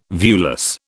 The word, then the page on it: viewless: British English